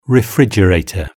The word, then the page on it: refrigerator: British English